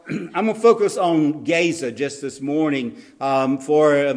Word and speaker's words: Gaza: I know today it's usually pronounced Gaza, but in the Hebrew it's pronounced Gaza.